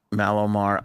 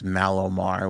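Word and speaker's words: Mallomar: Paige has never had a Malomar either. – Underrated. – Even the name stinks, Malomar. What does that even... – Right. – It's marshmallow. – It's mellow, not mallow, but we all pronounce it mallow.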